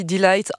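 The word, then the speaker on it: Deee-Lite: Groove is in the Heart, le plus grand tube de D-Light, je vais le prononcer correctement cette fois-ci, D-Light, en 1990 sur leur tout premier album World Click, un groupe très éphémère puisqu'il s'est arrêté en 1996.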